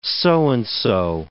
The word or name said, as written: so-and-so